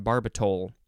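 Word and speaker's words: barbital: Yes, B-A-R-B-I-T-A-L. I want to say barbitol, but I think it's pronounced barbitol.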